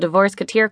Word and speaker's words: Divorceketeer: It's early September, and time to welcome a new member into the Divorceketeer Club, pronounced like Mouseketeer or Musketeer.